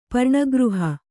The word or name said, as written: ಪರ್ಣಗೃಹ